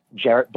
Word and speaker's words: Jarrett: I am at Jarrett Barrios on Twitter and on Instagram.